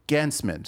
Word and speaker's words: Gancman: Gancman.